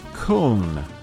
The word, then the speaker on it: Köln: Specifically, we were discussing the album Köln, K-Umlaut O-L-N, which is the Deutsch, or German pronunciation of what we call in the U.S.